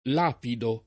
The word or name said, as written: lapidare